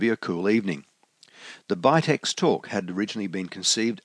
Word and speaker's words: BITX: Bill led off with the PowerPoint presentation establishing the correct pronunciation of BITX as Bitex, introducing the designer Ashar Farhan VU3ESE from far-off Hyderabad in India, and taking the group briefly through the history of the projects.